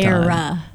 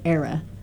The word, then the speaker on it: era: Mm, yes, that Not was that error, was a weird time. – Era. Era. That was a weird era. – Mm. E-R-A. Era.